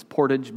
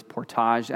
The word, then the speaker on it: portage: I don't know if there's any people here who know it as portage, but the word is portage, as they told me. It's got to have the French pronunciation.